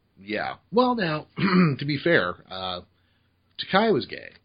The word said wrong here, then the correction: Takei: Or Takei, I'm sorry, rhymes with toupee.